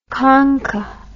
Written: khankah

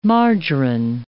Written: margarine

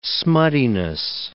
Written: smuttiness

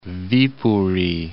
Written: Viipuri

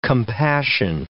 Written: compassion

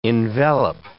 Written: envelop

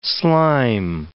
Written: slime

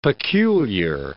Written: peculiar

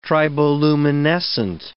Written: triboluminescent

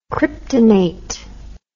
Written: kryptonate

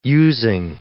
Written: Using

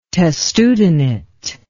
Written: testudinate

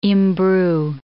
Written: imbrue

